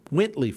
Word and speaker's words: Wintley: It'll be an immersive show with performances from English singer Jacob Collier, a poet, Ocean Vuong, musician Ajit, and gospel singer Whitney Phipps, or Wintley Phipps. Let me say that right.